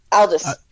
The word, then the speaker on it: Aldous: Aldous, sorry.